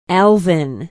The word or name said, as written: alvin